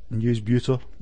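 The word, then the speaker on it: Newsbeuter: So all I can say is I struggled with it as well for a while, and then I eventually figured out that Newsbeater, as in New Beauty, or for the Aussies, it's Beaut, mate. Yeah, so Newsbeater.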